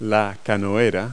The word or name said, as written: canoeist